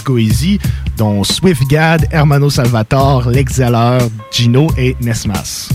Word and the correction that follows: Nems: Nems, c'est ça, Nems? – Nems, excuse-moi, je le prononce mal.